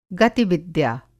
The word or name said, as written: गतिविद्या